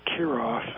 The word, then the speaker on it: Kierulff: And the last name is spelled K-I-E-R-U-L-F-F, but the L is silent. We pronounce the whole thing Kirov as if it were the Kirov Ballet from Leningrad, Russia.